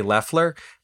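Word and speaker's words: Loeffler: we got some feedback letting us know that it's actually pronounced Kelly Leffler.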